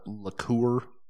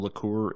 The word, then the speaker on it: liqueur: Well, now, when you say it's not a liquor, do you mean it's not a liqueur? – Yep. – Oh, okay. – Yeah. – Do you pronounce that word the same, liqueur and liquor? – No.